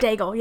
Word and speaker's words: Daigle: How do you say her name? – Daigle. Yeah, Lauren Daigle.